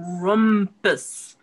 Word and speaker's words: Krampus: It's Compus. Crumpus. Compus. Crumpus. Not Krampus. It's not a crumpet.